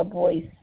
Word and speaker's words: Boyce: Paula Boyce.